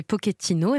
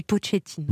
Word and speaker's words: Pochettino: Alors les prononciations, quand il était joueur, on disait Pochettino et maintenant qu'il est entraîneur, on dit Pochettino.